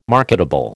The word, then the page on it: marketable: American English